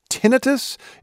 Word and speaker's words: tinnitus: It's a word that I think a lot of us pronounce as tinnitus, but I guess tinnitus is the formal way to pronounce it, ringing in the ears.